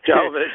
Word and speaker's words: Jovovich: Jovovich, thank you.